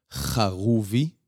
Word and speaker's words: Haruvi: My original last name is Haruvi. Mm. In Hebrew, it's pronounced Haruvi. Mm. The H and the R is different in Hebrew.